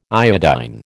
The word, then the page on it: iodine: American English